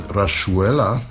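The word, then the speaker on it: Rayuela: It was Gotan Project with, and that's how they pronounce it, Rachuela. That's the Argentinian pronunciation translated as hopscotch in the linked translation,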